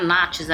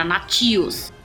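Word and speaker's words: Natchios: Ouvi uma entrevista do Miller uma vez falando que o nome dela não era Nachos, era Natios.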